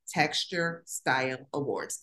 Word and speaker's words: Texture Style Awards: And I'm going to just take a moment to make it clear to everyone that the name, and you pronounced it right, is Texture Style Awards. There's no D after texture.